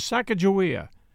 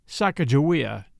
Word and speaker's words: Sacajawea: Her name, as we commonly pronounce it today, was Sacajawea, probably pronounced Sacajawea in reality, as many historians have determined, but opinions are still divided.